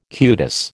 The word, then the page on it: cutis: American English